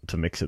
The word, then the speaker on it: Mx: So I have to put up my hand and say I had to ask a friend, but I believe it's pronounced mix.